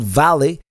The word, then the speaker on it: Valle: And actually we went to, it's called Valley. Or actually it's pronounced like Valley, but in Spanish. Like it's not pronounced like written, Valle. That would be the V-A-L-L-E.